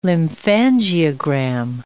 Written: lymphangiogram

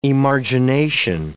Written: emargination

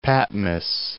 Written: Patmos

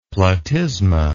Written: platysma

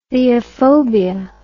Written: theophobia

